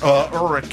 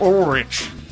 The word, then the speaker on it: Ulrich: Here is Lars Ulrich. How do you say this? Ulrich. How do you do that? – Spencer for Ulrich. – Hire. – Ulrich. – Spencer for Hire. Just remember that.